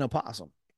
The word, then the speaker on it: opossum: Even when you say it correctly, an opossum, it just doesn't sound right.